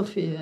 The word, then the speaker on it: Sofia: É Sofia mesmo, falei certo? Sofia, é.